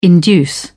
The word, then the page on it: induce: British English